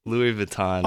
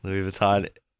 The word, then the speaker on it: Louis Vuitton: How do you pronounce it? – Louis Vuitton. – Oh, thank you. – Louis Vuitton.